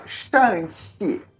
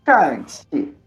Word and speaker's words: Shang-Chi: A aula do avião era pronunciar Shanxi, né? Shanxi. Que o Shan do CH a gente faz com a boca mais fechada e ele tem que ter um tom descendo, né?